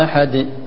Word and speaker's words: أحد: أحد أحد أحد ولا فيها شيء من الكسرة ولا غير لا أبدا تكون سكون مجرد عن الحركة مطلقا.